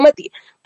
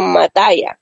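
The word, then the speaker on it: Mathias: Sí, él se llama Tom Mataya, algo así lo pronuncia, no Matías, Tom Matías se lee, pero lo pronuncia así como Mataya.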